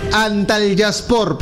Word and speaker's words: Antalyaspor: Señor, Antalyaspor, por favor. – Bueno, disculpe, amigo, ¿no? – Pronunciación de... Leo, Marquina, todos los sábados clases de turco a partir de esta semana, gracias.